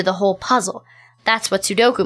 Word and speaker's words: Sudoku: Sudoku? – No, ma'am. It's pronounced Sudoku. It's a Japanese number game.